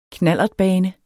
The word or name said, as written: knallertbane